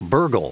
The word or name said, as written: burgle